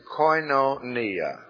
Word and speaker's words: koinonia: The word is koinonia. K-O-I-N-O, long O, nia.